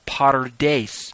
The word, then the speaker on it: PaRDeS: The acronym is pronounced PARDES. It's formed from the P-R-D-S, corresponding to the Hebrew ways or the Hebraic way of approaching Scripture.